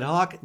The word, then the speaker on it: HoQ: At Hawk, 90% of the food served comes from Iowa farms and Iowa producers. Hawk, that's spelled H-O-Q, is open Monday through Saturday for dine-in, curbside pickup, and carryout.